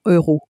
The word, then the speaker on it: euro: 100 euros. – Again, notice the way euro is said in French. – Euro. – Okay, you need to get used to that pronunciation.